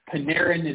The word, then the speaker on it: Panarin: Panarin is how you pronounce his name, if I recall correctly.